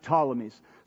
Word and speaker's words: Ptolemies: It starts with a P, but you just pronounce it Ptolemies.